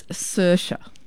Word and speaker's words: Saoirse: It's Saoirse.